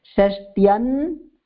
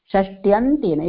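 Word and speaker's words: षष्ठ्यन्तेन: So when you are reading this, you should read it as shashtyan षष्ट्यन्तेन Shashtyantena. If you read it as Shashtya and you take a pause, then it will be difficult to do the pronunciation.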